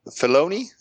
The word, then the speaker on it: Filoni: So, in a recent panel at San Diego Comic-Con, Dave Filoni, am I saying it right? – Yep.